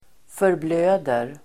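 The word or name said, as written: förblöder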